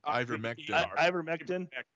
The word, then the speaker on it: Ivermectin: It's called Intervectin. Is that how you pronounce it? – Ivermectin.